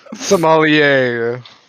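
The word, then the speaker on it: sommelier: I know the word visceral and Somalian. – Somalier. No, Somalier.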